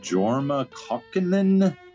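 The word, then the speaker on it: Jorma Kaukonen: We started the set off with a birthday salute to, I'm not sure if I know this guy's name exactly, Jorma Kokkonen? Is that how you would say his name, Steve? – That's how you would say it.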